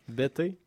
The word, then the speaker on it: Bettez: B-E-T-T-E-Z, je crois. – Bété? – Ouais. – C'est bon. – B-E-T-T-E-Z.